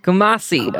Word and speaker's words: Kumasi: Kumasi, how do we say? See, I told you I'm going to butcher these. Kumasi? – Kumasi.